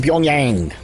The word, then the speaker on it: Pyongyang: But the sad news is from Pyongyang, that's how you pronounce it, North Korea.